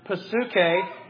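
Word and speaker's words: psuche (ψυχή): You know the word psyche, and it's spelled P-S-Y-C-H-E, right? Well, that comes from the Greek word psuche, which is where we get the word for soul or mind.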